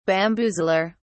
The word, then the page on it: bamboozler: British English